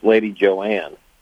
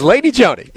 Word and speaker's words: Lady Joanne: Yeah, and she also, her name was spelled as if it should have been pronounced Lady Joanne. – That's it. But those of us in the know know that it's Lady Joni.